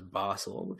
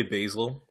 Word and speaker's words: Basel: I think the correct term for it is Basel. People say basil. I think basil is okay.